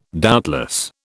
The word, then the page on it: doubtless: British English